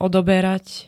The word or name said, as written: odoberať